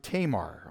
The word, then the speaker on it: Tamar: I was calling her Tamar. I was told that in English we pronounce it Tamar, okay, so I'm going to say Tamar if I can.